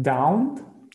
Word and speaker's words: doubt: Doubt. – Doubt. Yes, doubt. – Doubt. – The B isn't sounded at all. Just looks like it was D-O-U-T, but it's doubt with the B in there.